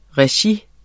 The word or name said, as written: regi